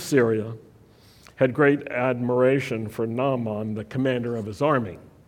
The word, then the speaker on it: Naaman: In southern English, you say Naaman, which rhymes with that guy. But in Hebrew, it also rhymes, but it's Naaman.